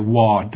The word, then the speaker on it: Waugh: Sorry, Hua, W-A-U-G-H. – W-A-U-G-H.